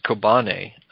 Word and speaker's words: Kobani: Well, you know, interestingly enough, well, we need to mention, because it ties into the media too, but it's pronounced Kobane. This is a Turkish word, and it means company, like business company.